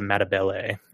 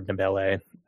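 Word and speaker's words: Ndebele: So Nkomo was his Soviet-backed rival, and while Mugabe was a Shona, which is one of the tribes, Nkomo was a Matabele or Nabele. They're both pronunciations are used, which is a different tribal group and kind of one of the original ones for Rhodesia.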